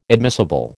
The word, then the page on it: admissible: American English